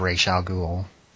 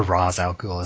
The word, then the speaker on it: Ra's al Ghul: Ra's al Ghul, or Ra's al Ghul as they pronounce it in this movie, in these movies.